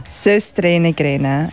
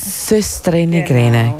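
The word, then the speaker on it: Søstrene Grene: Nostrenegrene. Man kann es ja fast nicht aussprechen. – Sösstrenegrene. – Sösstrenegrene, genau.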